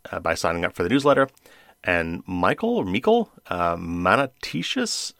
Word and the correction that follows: Manitius: oh my gosh, I butchered that one. Michael Manitius. And he entered, I keep saying that, but I don't know.